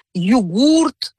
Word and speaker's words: йогурт: То есть как заимствовали йогурт, все было хорошо. Но люди стали употреблять это слово чаще, смещается ударение к началу слова, йогурт, массовая речевая практика именно такова. Соответственно, изменилась норма.